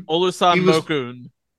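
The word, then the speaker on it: Olusanmokun: Olusan Mokun.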